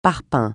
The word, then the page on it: parpaing: francais